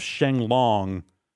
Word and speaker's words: Shenlong: I think, so here's another crazy reference here is, so the dragon's name is Shenron. – Yes. – Which is the Japanese pronunciation, more or less, of Shenlong.